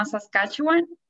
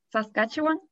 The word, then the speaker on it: Saskatchewan: En la University of Saskatchewan, en Canadá. ¿Lo dije bien? – Casi bien. Pero Es un nombre, es un nombre, es un nombre de que, es súper difícil, se llama Saskatchewan.